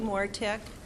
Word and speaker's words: Mortek: My name is Marie Mortek. – Could you spell that for us, please? – Sure. M-O-R-T-E-K.